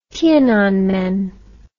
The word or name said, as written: tiananmen